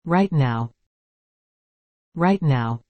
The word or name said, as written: right now